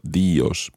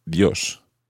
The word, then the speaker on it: Dios: This is because the combination of I-O normally is pronounced as one syllable. Think of the word Dios, Dios. It's not Dios, but Dios.